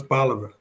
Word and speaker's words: Bolivar: And I've been mispronouncing it, huh? It's Bolivar.